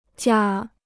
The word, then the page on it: 假: jià